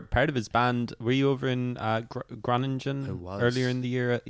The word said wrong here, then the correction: Groningen: Yeah, over in Groningen. – Groningen, sorry. – It's cool. I pronounced it wrong until I got there. I was corrected by a Dutch person, which is infinitely more embarrassing.